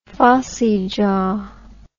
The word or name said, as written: phossy jaw